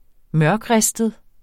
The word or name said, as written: mørkristet